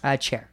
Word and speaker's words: Cher: Yeah, sunny and chair, but it's just pronounced chair, but it's spelled share.